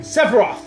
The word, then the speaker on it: Sephiroth: Sephiroth.